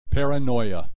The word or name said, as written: paranoia